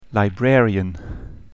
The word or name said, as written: librarian